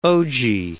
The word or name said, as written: ogee